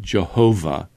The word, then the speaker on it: Jehovah: We would pronounce it Jehovah.